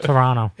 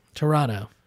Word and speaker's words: Toronto: How do you say Toronto? – Toronto. Like everybody, the way everybody says it. – Toronto. – It's Toronto. Toronto.